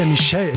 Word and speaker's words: Michelle: Chris Martin und Coldplay, We Pray, für euch auf SR1 und das ist der Davina Michael. Davina Michelle, Entschuldigung, so spricht man sie aus.